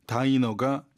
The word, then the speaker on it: diner: 여기선 다이너라고 읽어도 좋아요. 그래서 이 family diner라는 이 식당에 예약 대기 시간을 오래 끌도록 야기시킨 거죠. 그렇죠? 정답은 C번입니다. 발음은 다이너가 정확합니다.